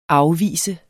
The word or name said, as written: afviser